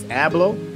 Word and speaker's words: Abloh: So, if you don't know, Virgil, I believe how you pronounce it is Abloh.